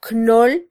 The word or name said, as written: knol